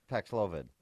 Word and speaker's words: Paxlovid: Paxlovid.